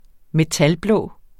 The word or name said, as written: metalblå